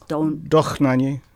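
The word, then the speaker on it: Dohnányi: Dohnanyi.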